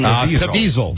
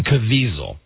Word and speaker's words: Caviezel: The guy that played Jesus, Jim Caviezel. – Jim Caviezel.